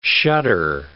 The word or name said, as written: shudder